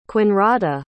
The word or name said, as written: Kuonrada